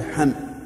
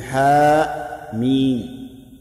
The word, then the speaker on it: حم: ثم قال الله تبارك وتعالى: حاميم. هذه كلمة مكونة من حرفين مهملين هجائيين، الحاء والميم، ولهذا ننطق بها باسمهما لا بلفظهما، فلا نقول حم بل نقول حاء ميم باسمهما، فهما إذن حرفان مهملان هجائيان يتركب منهما كلام الناس.